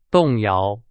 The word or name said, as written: 动摇